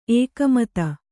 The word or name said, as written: ಎಗುಮತಿ